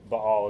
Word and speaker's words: Baal: and she was a priestess to Baal as well. So, one of the things you'll notice is the way I'm saying that word Baal, that's really the correct pronunciation.